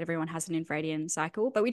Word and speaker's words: infradian: You said it's so it's in how do you say that? Infraradian, infradian. – Infradian, yeah.